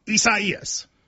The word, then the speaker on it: Isaias: A lot of people, prayers to everybody involved, but it is Isaias. – Is that how you say it, though? – You do.